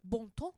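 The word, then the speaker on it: Bon Temps: But it's spelled, for those of you who want to know, BONTEMPS, Bon Ton.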